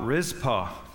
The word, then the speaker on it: Rizpah: We're going to talk about Rizpah tonight. R-I-Z-P-A-H is how you spell it, Mike Case, but it is in 2 Samuel 3 right there.